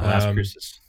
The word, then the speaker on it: Las Cruces: Takes you through, once you're in New Mexico, La Cruces. – Okay. – Las Cruces. I probably said that wrong. Las Cruces, bro. – Yeah, Las Cruces.